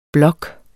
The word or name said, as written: blok